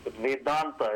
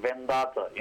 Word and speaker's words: Vedanta: So it's Vedanta. It's not Vendata.